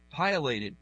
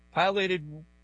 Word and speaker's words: Pileated: Pilotted woodpecker? Is that the way you pronounce that word? – Pilated. – Pilated. Sorry about that. – Really big one. – Yeah. Pileated woodpecker hangs upside down from the bird feeder.